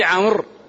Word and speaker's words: عمرو: فقال قائلهم، قال لأنه سرق واو داود، فداود هناك واو تنطق ولا تكتب، وفي عمرو تكتب ولا تنطق.